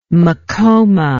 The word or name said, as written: macoma